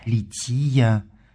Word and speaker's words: лития: церковнославянское ударение этого слова «лития».